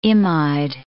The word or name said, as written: imide